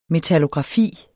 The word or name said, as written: metallografi